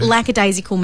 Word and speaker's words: lackadaisical: I think a lot of people do say lackadaisical because the shortened version is lax, so they think it's lackadaisical, but it's actually lackadaisical, which means lacking enthusiasm and determination, or basically lazy.